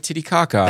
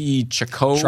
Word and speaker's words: Titicaca: We are pronouncing it correctly. – When we say Lake Titicaca. – That's what it's pronounced. – That is how they say it.